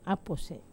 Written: apose

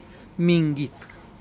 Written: mingit

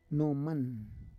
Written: noman

